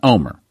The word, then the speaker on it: Olmert: I said Olmert, but again, the correct pronunciation is Omer, and that's what she heard in the dream, Omer.